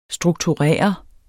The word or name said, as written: strukturere